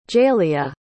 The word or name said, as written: jayliah